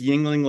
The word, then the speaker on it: Yingling: It's Yingling like the beer, except it's spelled differently.